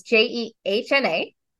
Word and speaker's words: Jehna: So it's Jehna.